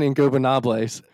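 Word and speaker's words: Ingobernables: Los Faction in Los Faction in Gobernables.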